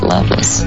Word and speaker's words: Lovelace: His name is spelled Lovelace, but they pronounced it Loveless.